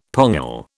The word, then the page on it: pongal: British English